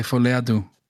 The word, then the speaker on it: Folie à Deux: I think I would think it's Folie à Deux. Folie à Deux, right? – That's exactly what I was going to say. Folie à Deux.